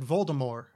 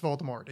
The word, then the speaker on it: Voldemort: Because at one point she said, well, it's supposed to, I always pronounce it Voldemort, like with no T at the end, like a French saying. And I'm like, well, cool, that's not the way it's pronounced in the books. – Yeah, like, you don't get to be right. Sorry, like, it's just, it's like a typo. Like, it's out. Sorry, like, it's no longer in your hands. That's, it's pronounced Voldemort.